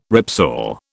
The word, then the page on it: ripsaw: British English